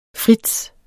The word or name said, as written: frits